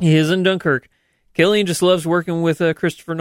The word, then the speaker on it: Cillian: And it was, and I love the fact that Cillian Murphy, is that how you say his name? – I believe so, yes.